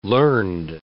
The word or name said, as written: learned